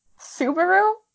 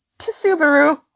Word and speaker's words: Subaru: Subaru is not, in fact, pronounced to Subaru. – Wait a second. Does Subaru have a silent T at the beginning? – It does not, but for some reason, like, it's spelled with a S, but for some reason I was like, oh, Subaru, like tsunami. Subaru had a T, and I don't know why.